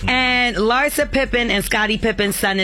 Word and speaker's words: Larsa: Larsa. That's how you say it.